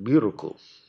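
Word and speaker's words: miruku (ミルク): and we looked up at the character for milk and pronounced it miruku.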